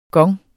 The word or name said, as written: gong